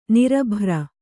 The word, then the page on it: ನಿರಭ್ರ: kannada